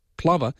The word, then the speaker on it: plover: The bird is properly pronounced plover because Graham, who's Scottish, was the person that told us the proper pronunciation.